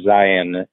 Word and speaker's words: Zeihan: For those who are not familiar, Peter Zion, it's pronounced like Zion National Park, but it's spelled Z-E-I-H-A-N,